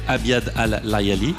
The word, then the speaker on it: Abiad al-Layali: Ton premier gros morceau remarqué s'appelle, je pense que je vais mal le prononcer, mais Abiad al-Layali. – Oui, c'est bien. – C'est juste. Ça, ouais.